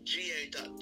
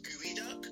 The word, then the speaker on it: geoduck: It's spelled geoduck, G-E-O, the duck. It's actually pronounced gooey duck, because I watched on YouTube this lady like properly like prepare one, and they pronounce gooey duck,